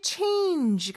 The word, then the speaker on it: change: 그 다음에 change 같은 경우는 마지막에 j 발음 주의해 주시고요. 그냥 g가 아니죠. j 이런 소리고요.